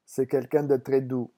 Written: c'est

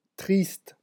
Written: triste

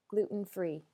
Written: gluten-free

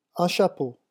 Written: un chapeau

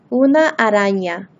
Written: una arana

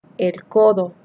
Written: el codo

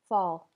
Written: fall

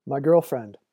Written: my girlfriend

